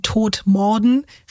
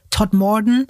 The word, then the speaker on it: Todmorden: Für uns Deutsche gelesen eben Todmorden, richtig ausgesprochen heißt die damals circa 14.000 Einwohnerstadt in West Yorkshire Todmorden.